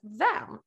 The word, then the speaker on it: Vaam: för du är ju grundare och CTO på VAM, eller eh, visst uttalade jag det rätt? VAM? – Det var helt rätt, helt rätt.